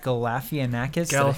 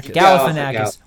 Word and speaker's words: Galifianakis: Due Date, new film from Todd Phillips, starring Robert Downey Jr. and Zach Galifianakis. – Galifianakis.